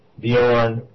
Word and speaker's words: Bjorn: It's Eric, A-R-I-K, Bjorn, B-J-O-R-N, A-R-I-K.